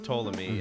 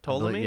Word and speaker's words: Ptolemy: So today we're going to cover Cleopatra's brother and sister, but mainly the brother, Ptolemy the 13th, who is the 13th Ptolemy in a long, long line of Ptolemies spelled like pterodactyl, P-T-O-L-E-M-Y? Ptolemy? – Yes. – Ptolemy? – Yeah, Ptolemy. – Yeah.